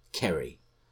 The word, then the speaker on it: Qerry: So I'm going to call her Kerry. Yes, spelt with a Q. So Q-E-R-R-Y, Kerry.